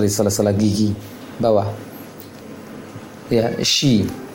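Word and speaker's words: Shi (شِ): Ya, ingat dalam pengucapan huruf Shin, jangan sampai terlalu ke depan bibirnya. Bahkan kalau bisa diusahakan bibirnya tidak monyong ke depan. Shi, tidak jauh beda dengan ketika membaca hamzah kasrah. I, shi.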